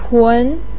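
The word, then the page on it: tun: tone 2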